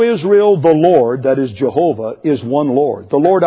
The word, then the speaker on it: Jehovah: And when you bring that in with the German pronunciation, which pronounces the W as a V, you come with the word Jehovah.